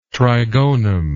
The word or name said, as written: trigonum